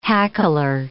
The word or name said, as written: hackler